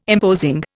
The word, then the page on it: imposing: Indian